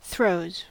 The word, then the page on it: throes: American English